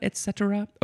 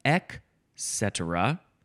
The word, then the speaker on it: et cetera: There are two uncommon pronunciations. Ek cetera. There's no T sound. It's ek cetera or ik. Ek or ik.